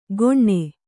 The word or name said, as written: ಗೊಣ್ಣೆ